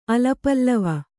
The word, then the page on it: ಅಲಪಲ್ಲವ: alapallava